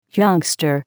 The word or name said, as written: youngster